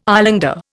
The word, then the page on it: islander: Indian